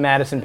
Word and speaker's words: Matteson: Uh, my website is my name, Matteson Perry, but it's spelled M A T T E S O N P E R R Y dot com.